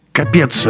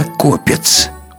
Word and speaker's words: Копец: Капец расходомеру. Требует замены». Клиент, услыхав знакомое созвучие, чинно заявляет. «Уважаемый, не капец, а копец. Ударение на «о».